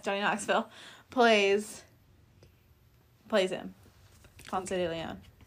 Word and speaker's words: Ponce de Leon: Because all I imagine was Ponce de Leon. – Yes, I said it correctly. Because then she goes, and how the white people say it.